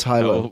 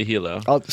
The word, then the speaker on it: Hilo: How do you say it, though? – Hilo. – Oh, that's what she said. He. I said hi-lo.